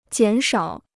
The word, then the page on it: 减少: jiǎn shǎo